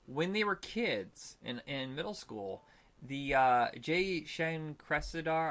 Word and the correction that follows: Jay Chandrasekhar: I'm not going to say his name right, Jay Chandrasekhar.